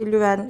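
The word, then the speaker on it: Leuven: Belçika'da Katolik Lüven Üniversitesi doğru mu telaffuz doğru, ediyorum? Ee, doğru.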